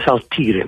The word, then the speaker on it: Saltire: Si chiama Saltire Flag, si scrive Saltire, ma si legge Saltire Flag.